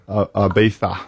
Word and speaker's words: Ibiza: Ibiza.